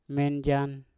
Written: menjan

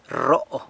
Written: rro

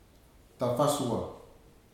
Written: tafasua

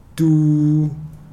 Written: tu

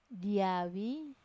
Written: diavi

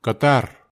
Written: katar

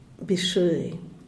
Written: bishuey

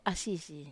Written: asisi

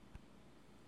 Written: sintlay

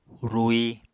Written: rui

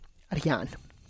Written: rrian

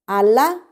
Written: alha

